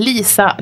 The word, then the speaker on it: Liza: Jag måste bara fråga, när man uttalar ditt namn på amerikanska, blir det Lisa Laserov? – Alltså jag försöker ju få dem att säga Lisa, men då stavar de det helt plötsligt med L-E-E-Z-A.